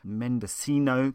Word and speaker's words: Mendocino: very beautiful Mendocino County, as I now know to pronounce it, right up to the Oregon border through the Redwood Forest.